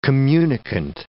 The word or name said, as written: communicant